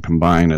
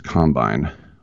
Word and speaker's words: combine: As we're getting ready for the combine, and as I like to point out in football, you always put the emphasis on the wrong syllable. It's not offense, defense, and combine. It's offense, defense, combine.